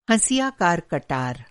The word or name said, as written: हँसियाकार